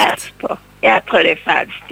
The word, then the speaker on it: Castor: Jag påstår att de båda stjärnorna i tvillingarnas stjärnbild heter Castor och Pollux, tror jag man uttalar det. – Rätt.